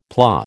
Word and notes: PLA: American English

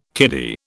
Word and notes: kiddy: British English

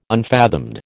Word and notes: unfathomed: American English

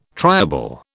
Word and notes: triable: British English

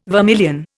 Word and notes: vermilion: Indian

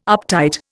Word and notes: uptight: Indian

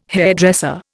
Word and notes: hairdresser: Indian

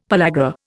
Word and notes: pellagra: Indian

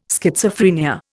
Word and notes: Schizophrenia: Indian

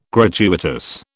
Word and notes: gratuitous: British English

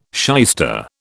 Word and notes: shyster: British English

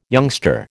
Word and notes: youngster: American English

